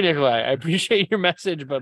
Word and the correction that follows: Nikolaj: like, it's pronounced Nikolaj. – Nikolaj.